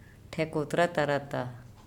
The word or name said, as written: ThEkotratarata